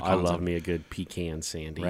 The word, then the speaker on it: pecan: Pecan, which I feel like we're saying it right, right? – Not pecan. – Pecan. There's also a couple other, I saw that there's a couple other, even English people say it a different way, but it's regionally pronounced. Okay. We go with pecan. – Pecan. – But I think down south it's like pecan. Pecan pie.